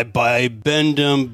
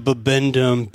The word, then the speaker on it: Bibendum: Also goes by bibendum, babendum, bibendum. Sounds like there's multiple pronunciations online about that.